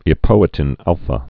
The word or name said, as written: epoetin alfa's